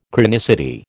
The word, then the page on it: chronicity: American English